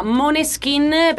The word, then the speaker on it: Måneskin: Protagonisti per quanto riguarda l'Italia, l'abbiamo detto, i Maneskin, che in realtà cioè il gruppo, il nome del gruppo si pronuncia Måneskin, perché danese significa chiaro di luna.